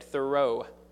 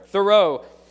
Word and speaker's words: Thoreau: I would like to share first Henry David Thoreau, I'm pretty sure I pronounced that right, Thoreau. It's like throw, but there's an O before the R. All right, Thoreau.